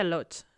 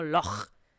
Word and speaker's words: loch: Aquí, lago no es lake, es loch, en gaélico. Y no se pronuncia loch, se pronuncia algo así como loch, para el que lo entienda.